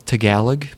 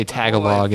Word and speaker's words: Tagalog: It's pronounced Tagalog, please. – Is it really? – Yes. Don't say Tagalog in front of a Filipinese person.